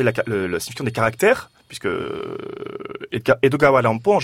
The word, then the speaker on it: Edogawa Rampo: En japonais, se prononce Edogawa Rampo, qui est en fait la transcription phonétique d'Edgar Allan Poe.